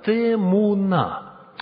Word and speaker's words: темуна: Вот это слово «изображение» является переводом древнееврейского «темуна», ударение на последний слог, «темуна».